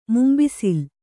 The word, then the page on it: ಮುಂಬಿಸಿಲ್: Kannada